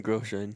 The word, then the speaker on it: Grosjean: I am. Grosjean. Roman Grosjean. – Yes. I knew I said his last name wrong.